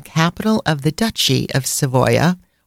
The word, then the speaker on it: Savoia: Also, you've probably all heard of the Savoy dynasty, and Savoy is pronounced Savoia in Italian